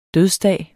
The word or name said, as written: dødsdag